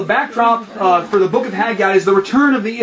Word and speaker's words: Haggai: Am I pronouncing it right? – Haggai, thank you.